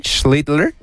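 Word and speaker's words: Schlittler: Sí, lo pronuncié bien. Schittler. – Sí.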